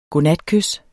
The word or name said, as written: godnatkys